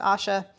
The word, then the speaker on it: Ayesha: Asha, A Y E S H A. One of the characters to come up. It's pronounced Asha, although if you want to pronounce it some other way, that's all right.